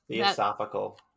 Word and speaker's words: Theosophical: Theosophical.